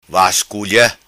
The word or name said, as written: vasculia